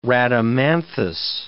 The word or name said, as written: Rhadamanthus